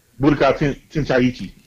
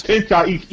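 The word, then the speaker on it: Budokai Tenkaichi: Budokai Tenchaichi. – Yes. – Tenchaichi.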